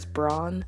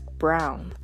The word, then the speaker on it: Braun: And something I noticed for season 4 is that even though I'm inclined to say it as Braun, and that's usually how I hear other folks say it, the characters in the show actually pronounce Reiner and Gabby's surname as Brown.